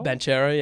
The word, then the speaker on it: Banchero: I can never get this guy's name right, but Banchero. – Yeah, yeah. – I butchered that. – But Pablo Banchero? – Banchero, yeah. And he's put on weight.